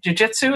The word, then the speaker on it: jiu-jitsu: And now let me make, hopefully I'm pronouncing it right, jiu-jitsu, is that right? – Mm-hmm.